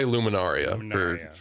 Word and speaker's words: Luminaria: I would say Luminaria for anyone asking.